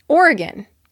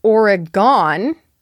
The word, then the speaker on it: Oregon: Oregon is not pronounced Oregon because the last syllable is unstressed. The O simply turns to an U sound.